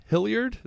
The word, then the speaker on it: Hilliard: Hilliard, is that how you say his name? – Oh yeah.